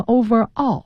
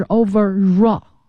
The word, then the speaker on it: overall: 嗯，再往下一个词是 overall， overall 呢它是个组合在一起的， over 和 all 放在一起，有同学念成 overall，那不对，是 overall 啊，它要连在一起读，所以 R 结尾的词加元音开头的这种连读是 raw 啊，所以你会听到 overall 是全部的总的这个意思，形容词。